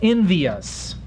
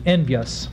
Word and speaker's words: envious: For instance, when Irving mistakenly uses a trisyllabic pronunciation of envious, which should be envious. Here, synesthesis should come into play. Ah, there's a new word for you. Synesthesis, S-Y-N-E-Z-E-S-I-S, is a name given to a common linguistic phenomenon, the fusion of vowels and adjacent syllables into a consonant vowel combination.